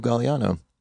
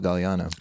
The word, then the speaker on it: Gagliano: At Rico Galliano. But the problem is no one knows how to spell Galliano. – I have no idea how to spell your last name. – Silent G in there. G-A-G-L-I-A-N-O.